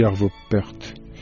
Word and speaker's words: Arvo Pärt: J'ai fait dans ce livre, Yannou, c'est ma manière de vous répondre, j'ai fait une lettre à Arvo Pärt, je crois qu'on prononce dans sa langue, on dit Arvo Pärt.